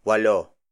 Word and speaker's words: walo: Next on the list is walo.